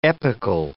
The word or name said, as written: epical